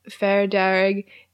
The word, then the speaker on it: Far Darrig: The name Far Darrig is an anglophone pronunciation of the Irish words fear, Derg.